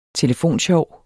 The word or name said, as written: telefonsjov